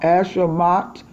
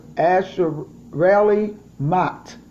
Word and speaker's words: Acharei Mot: Hello, I thank God for the Torah portion number 29, which is called Ashamot. And the word is spelled A-C-H-A-R-E-I, and Mot is M-O-T. It's two words, Asherali Mot.